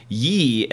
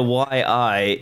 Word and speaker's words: Yi: This is the Yi, and I've been pronouncing this incorrectly, and people have thanks for all your input. It's not the YI. It is the Yi action cam.